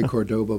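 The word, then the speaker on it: Córdoba: Some people say Cordoba, but the Spanish say Cordoba. They put the accent on the first syllable.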